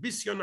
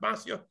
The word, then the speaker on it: Bisya: We actually pronounce it Bisya, not Basya.